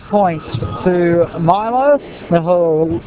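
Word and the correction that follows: Vilović: Milo Vilovic is the correct pronunciation, but thankfully we can call him Milo during the game.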